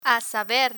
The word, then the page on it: a saber: Spanish